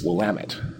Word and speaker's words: Willamette: And one pronunciation won. It's Willamette. – Right. – Damn it. That's how you remember it. – Willamette. – Willamette, damn it. – All right. – But the original word was probably pronounced something like Wallamoot.